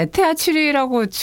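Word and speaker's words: ETH Zürich: 예, 이것을 독일어로 도대체 뭐라고 읽는지 팀 격동의 독일 통신. – 이게 너무 낮은 수준의 경쟁이어서 그렇긴 한데 그냥 에테하 치리라고 주로 읽고요.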